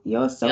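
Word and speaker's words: YOASOBI: How do you pronounce his name? – Yo Asobi? Yep.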